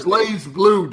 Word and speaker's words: BlazBlue: Everybody knows it's Blaze Blue, dog.